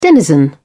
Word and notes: denizen: British English

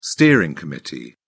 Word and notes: steering committee: British English

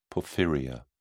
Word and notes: porphyria: British English

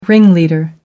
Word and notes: ringleader: American English

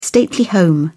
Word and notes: stately home: British English

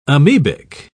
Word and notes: amoebic: British English

